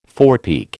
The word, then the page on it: forepeak: American English